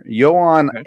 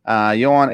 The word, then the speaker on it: Johan: But it's a pitcher, Johan Adon. It's spelled like Joan. Johan Adon.